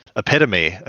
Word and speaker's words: epitome: Hey, I didn't learn how to pronounce epitome until I was like 22. I always just read it in books and thought it was epitome.